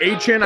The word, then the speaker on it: Achane: Jameis Winston, Devon Achane or Achan. – It's I think Achan. He's trying to change the it's pronunciation. Achan now, Mike. It's Achan.